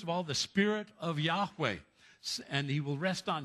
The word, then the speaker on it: Yahweh: First of all, he's called the Spirit of Yahweh. Notice Lord all in capitals. Translates the Hebrew word YHWH, pronounced Yahweh.